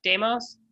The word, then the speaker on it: Deimos: Ares had two children, Phobos and Deimos? Demos? – How do you spell it? – D-E-I-M-O-S. Deimos? – Deimos.